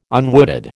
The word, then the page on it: unwooded: American English